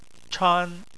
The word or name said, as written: chan1